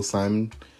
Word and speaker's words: Shimon: It's spelled Shimon, but I'm I'm pretty sure it's pronounced still Simon. Just I'm sure it's still pronounced Simon. It's just spelled differently.